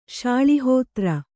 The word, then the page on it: ಶಾಳಿಹೋತ್ರ: śaḷi hōtra